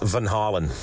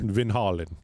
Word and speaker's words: Van Halen: Let's go and this is the proper pronunciation. – Okay. – Apparently, it should be pronounced Van Halen. – Just like Kelsey said, Van Halen.